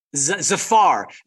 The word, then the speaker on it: Zafar: So Zader Massoud, is that how you pronounce his name? – Zafar Massoud. You're forgiven. It's a Pakistani name.